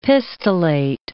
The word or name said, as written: pistillate